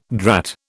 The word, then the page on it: drat: British English